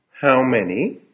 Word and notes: how many: American English

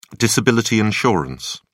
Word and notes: disability insurance: British English

